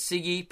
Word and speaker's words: Siggy: Yes, my name is Siggy, pronounced like Siggy, as in cigarette butt.